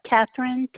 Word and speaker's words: Kathryn: Catherine Kaplan. – Can you spell that first name for me? – K-A-T-H-R-Y-N.